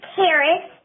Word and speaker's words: Karis: My name is Karis, spelled K-A-R-I-S, and it rhymes with Paris.